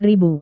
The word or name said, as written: ribu